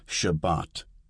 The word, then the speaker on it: Shabbat: Sephardic. This is the modern Israeli pronunciation that's used in most liberal synagogues in the United States. An example is Shabbat, Sabbath, with the accent on the second syllable.